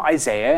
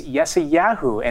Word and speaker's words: Yeshayahu: Now, some of us call him Isaiah, and other of us call him Isaiah, but those pronunciations are actually both wrong. His name in the Hebrew is Yeshayahu, and it means God is salvation.